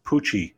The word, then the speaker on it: Pucci: 1982 Mark Six Pucci edition. that rhymes with Gucci.